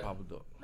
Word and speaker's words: Papa Doc: Papa Doc, but it's pronounced Papa Duck.